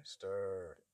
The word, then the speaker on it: Stour: How do you pronounce Stour? – Stir. They sort of, they flatten that yes.